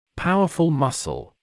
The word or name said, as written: powerful muscle